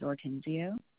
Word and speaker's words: Ortenzio: My first name's Grace, and my last name is Ortenzio. – Spell your last name for me. – O-R-T-E-N-Z-I-O. – Z-I-O or V, as in Victor? – Z, like zebra.